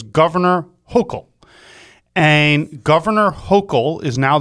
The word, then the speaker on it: Hochul: I learned how to say Governor Hochul. It's interesting. I didn't know how to say her name. And if you remember, it's H-O-C-H-U-L. And the H appears silent.